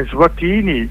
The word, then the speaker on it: Eswatini: Eswatini, così si pronuncia? – Sì, adesso si chiama Eswatini,